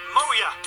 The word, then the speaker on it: Moea: I had a message from a guy called Gary, who tells me he's formed a band with some friends, and they're called Moya. But you spell it M-O-E-A. So it's Moya spelled wrong.